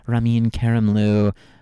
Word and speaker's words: Ramin Karimloo: They're going to be streaming, I don't think it's Playbill, but Andrew Lloyd Webber is releasing the Sierra Bogus Ramin Karimloo.